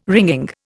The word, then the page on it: wringing: Indian